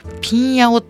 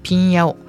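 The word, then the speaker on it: 平遥: であのいつぞやも、まあ、北京のお話はいたしましたけれども今日は日本あのえ漢字で書くと「平らにはるか」って書くんですけども「ピンヤオ」って読むんですね。この「平洋」日本語では「平洋」と読みますけれども「ピンヤオ」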